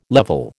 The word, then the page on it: level: American English